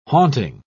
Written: haunting

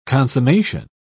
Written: consummation

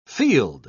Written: field

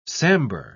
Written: sambar

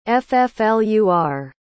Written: fflur

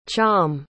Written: cham

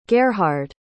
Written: gerhard

